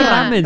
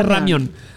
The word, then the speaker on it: ramyeon: No se dice ramen, se dice ramyeon.